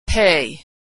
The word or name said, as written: pay